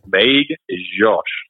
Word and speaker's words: Magdziarz: My last name is Magdziarz, but they said it was Magdziarz with like a very soft J.